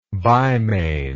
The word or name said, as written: bimane